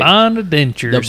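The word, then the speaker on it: St. Bonaventure: I'm going with St. Bonaventures. I don't even know if I said that right. – St. Bonaventures.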